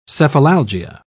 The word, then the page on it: cephalalgia: British English